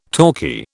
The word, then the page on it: talkie: British English